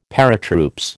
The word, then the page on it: Paratroops: American English